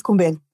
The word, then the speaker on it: Kumbel: My name is Kumbel, and Kumbel is spelled with a K. – Mm-hmm. – Okay. And you know, fresh. – Fresh. – Yes. F-R-E-S-H.